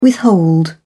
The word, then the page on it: withhold: British English